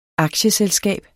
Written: aktieselskab

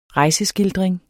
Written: rejseskildring

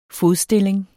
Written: fodstilling

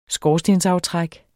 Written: skorstensaftræk

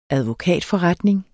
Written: advokatforretning

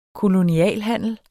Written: kolonialhandler

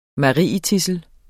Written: marietidsel

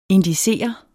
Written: indicere

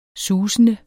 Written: susende

